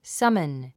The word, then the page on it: summon: sʌ́mən; サモン